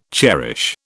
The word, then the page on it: cherish: British English